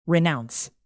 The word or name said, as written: renounce